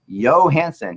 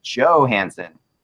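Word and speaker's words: Johansen: What the heck are you doing when you keep calling the center for the Preds Johansson? He is not from Europe. It is pronounced Johansson.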